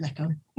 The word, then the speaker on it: Nikon: they do tend to see Nikon a lot more. But we're Scottish, we're awkward, we say it Nikon.